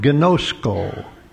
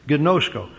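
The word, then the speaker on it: ginōskō: One is the word gnosko. In the anglicized form, we spell it G-I-N-O-S-K-O. Gnosko.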